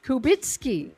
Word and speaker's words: Kubicki: It's Adrian, A-D-R-I-A-N, how it's spelled, rather. And K-U-B-I-C-K, pronounced Kubicki.